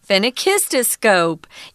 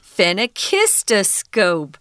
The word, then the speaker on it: phenakistoscope: So phenakistoscope. Yeah, it's also very long, but don't let it frighten you. It's not too hard to say once you break it down into syllables phenakistoscope.